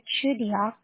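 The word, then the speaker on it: Chugiak: I'm sorry, that's Chudiar? – Oh, Chudiak, I spelled it. – Can you please spell it for me, please? – Yeah, C-H-U-G-I-A, and then K.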